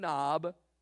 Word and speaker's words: Nob: It is spelled just like it sounds, N-O-B.